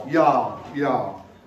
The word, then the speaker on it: y'all: Y'all. – I've got too many syllables. It's one syllable.